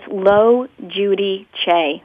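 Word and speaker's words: Logiudice: It's Lo Judy Che. It's Italian. So it means the judge.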